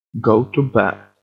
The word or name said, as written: go to bat